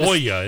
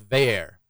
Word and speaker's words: Veilleux: Not even close. His last name his last name is spelled V-E-I-L-L-E-U-X. So obviously it's French. – Valix. – So I was thinking it was like Veyu, Veya. No, it's pronounced Vayer. V-A-Y, the phonetics is V-A-Y-A-I-R.